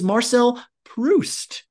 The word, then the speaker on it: Marcel Proust: It's Marcel Proust, if I am doing it right this time.